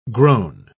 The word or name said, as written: grown